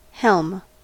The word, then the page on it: helm: American English